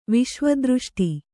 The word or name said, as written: ವಿಶ್ವದೃಷ್ಟಿ